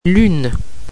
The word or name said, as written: lune